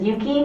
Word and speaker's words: Yuki: Okay, my name is Risa Yuki. It's spelled R-I-S-A. Last name is Yuki, Y-U-K-I.